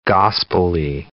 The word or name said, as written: gospelly